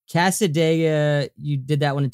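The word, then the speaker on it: Cassadaga: Casadega, is that how you pronounce this? – Yep, Casadega.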